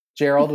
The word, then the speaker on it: Gerald: The Joe Schmo Show was way up there because it was so high concept, and my character's name was Gerald with a hard G.